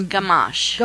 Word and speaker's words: Gamache: Pronounce Marsha's last name. – It's Gamache. – Gamache Band. I guess I better... – G-A-M-A-C-H-E.